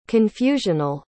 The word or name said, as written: confusional